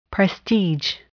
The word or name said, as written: prestige